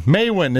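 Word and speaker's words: Maewyn: Patrick's real name is Maywin. Maywin is how it's pronounced.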